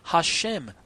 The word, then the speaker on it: Hashem: At other times it should be pronounced, the Stone Edition goes on to say, at other times it should be pronounced Hashem, literally the name.